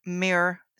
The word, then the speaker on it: Muir: And just for those who heard the URL just now, that's the website for the John Muir. Am I saying that right? – Correct. For the John Muir Land Trust. – I said it right.